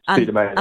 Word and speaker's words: Pseudomonas: it was a bacteria called Pseudomonas, I think. Am I pronouncing that correctly? – Yeah, Pseudomonas.